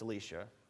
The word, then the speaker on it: Elisha: It looks the same. Eli- it looks like Elijah and, Elisha, but it's Elisha. So it's, it is Elisha, so at least pronunciation wise, you can tell them apart for the story.